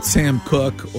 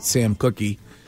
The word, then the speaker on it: Sam Cooke: That's the great Sam Cooke, or as Shime pronounces it, Sam Cookie.